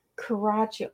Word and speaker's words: Coraccio: Coraccio. I wasn't even close. – It's Italian. It's okay. The CC in Italian is a ch.